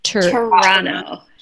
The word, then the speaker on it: Toronto: Is it Toronto? How do I say it? – Toronto. – Toronto. – Yeah. We don't say the last T.